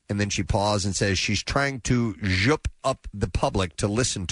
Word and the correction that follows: zhuzh: Well, it's spelled Z-H-U-Z-H. Zhush. Zhush up the public.